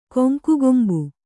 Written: ಕೊಂಕುಗೊಂಬು

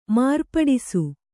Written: ಮಾರ್ಪಡಿಸು

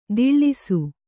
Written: ದಿಳ್ಳಿಸು

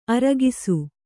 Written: ಆರೊಗಿಸು

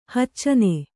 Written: ಹೊಚ್ಚನೆ

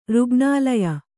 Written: ರುಗ್ಣಾಲಯ

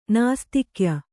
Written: ನಾಸ್ತಿಕ್ಯ

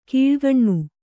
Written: ಕೀಳ್ಗಣ್ಣು

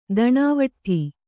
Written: ದಣಾವಟ್ಠಿ

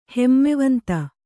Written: ಹಿಮವಂತ

